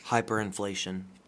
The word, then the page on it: hyperinflation: American English